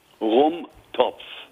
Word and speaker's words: Rumtopf: Rumtopf. R-U-M-T-O-P-H. Topf.